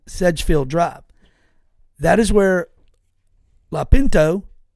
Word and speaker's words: Lopinto: I pronounce his name, I'm sorry. Lopinto. It's L-O-P-I-N-T-O. Lopinto.